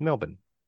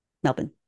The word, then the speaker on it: Melbourne: Oh, which, by the way, I had somebody tell me that they love the fact that I pronounce Melbourne like an Australian. – That's very good, because a lot of Americans call it Melbourne. – Yeah, exactly. – Which is, and you pronounce it nice, it's Melbourne.